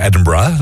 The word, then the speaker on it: Edinburgh: Nou, zo moet je het ook uitspreken, Edinburgh. Dat is een mooie plaats natuurlijk in het Schotse.